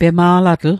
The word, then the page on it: pema'latl: Mi'gmaq